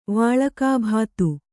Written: ವಾಳಕಾಭಾತು

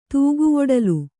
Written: ತೂಗುವೊಡಲು

